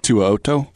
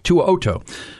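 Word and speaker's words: To'oTo'o: How do we say his name out of Alabama? – From Bama. – Tuoto. – Tuoto.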